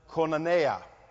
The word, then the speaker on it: koinonia: And it revolves around a word that I'm going to spell for you, and it's simply this, K-O-I-O-N-I-A. I'm going to dare to pronounce it koinonia.